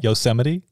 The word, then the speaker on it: Yosemite: Yosemite. Wait, is that how you say it? – Yosemite? – Yosemite. – Yeah.